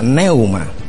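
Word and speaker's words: pneuma: En el griego, eso sí ya lo he escuchado, algunos que hombres de Dios que dice que es neuma, P-N-E-U-M-A, y se pronuncia neuma,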